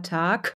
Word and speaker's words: Tag: Also die besagt, dass am Ende, wenn ein weicher Laut am Ende steht, zum Beispiel bei Tag, da schreibt man ja mit G, dann sagt man aber Tag mit K.